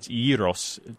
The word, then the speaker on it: gyros: Because the word comes from Greek and it means turning, and it's gyros to mean a turning. That's the Greek pronunciation, and it does have an S.